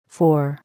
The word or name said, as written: Four